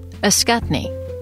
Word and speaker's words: Ascutney: Ascutney. Just like a cut knee.